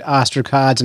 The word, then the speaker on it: ostracods: I said ostracods, and I said it properly.